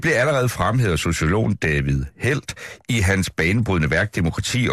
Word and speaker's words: David Held: Og det er godt nok et sted, hvor du så citerer en David Held, skal vi sige det? Er det korrekt udtalt? – Ja, David Held kalder jeg ham.